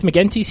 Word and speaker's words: McGinty: Keith McGinty's here. Hard G.